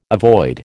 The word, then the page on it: avoid: American English